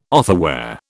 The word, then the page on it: otherwhere: British English